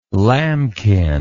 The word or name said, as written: lambkin